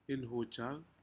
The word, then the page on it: inhujac: Aneityum